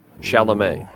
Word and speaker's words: Chalamet: For Milo, I cast Timothy Calumet. – Chalamet, I'm pretty sure.